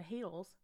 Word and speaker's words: Hadal: It's spelled H-A-D-A-L-S, and it should be Hadals, but it's Hadals. And they make a specific point to be, like, Hadal like cradle.